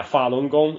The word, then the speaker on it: Falun Gong: Falun Gong. – Yeah, Falun Gong.